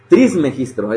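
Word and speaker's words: Trismegisto: Por cierto, ya, le, ya estoy leyendo de lleno a Hermes Trimegistro. Trismegistro es la forma correcta de pronunciarlo.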